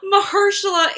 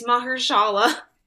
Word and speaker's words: Mahershala: Mahershala is how the Southerns pronounced it. It's Mahershala.